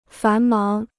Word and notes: 繁忙: fán máng

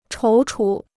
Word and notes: 踌躇: chóu chú

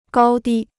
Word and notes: 高低: gāo dī

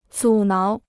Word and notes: 阻挠: zǔ náo

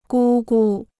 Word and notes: 姑姑: gū gu

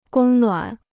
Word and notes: 供暖: gōng nuǎn

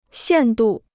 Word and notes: 限度: xiàn dù

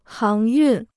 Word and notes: 航运: háng yùn

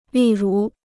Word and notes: 例如: lì rú

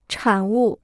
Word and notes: 产物: chǎn wù

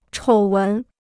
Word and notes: 丑闻: chǒu wén

